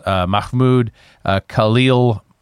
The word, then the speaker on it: Mahmoud Khalil: The guy's name is Mahmoud Khalil. Did I say that right? – Mahmoud think so. – Khalil? – Sounds good to me.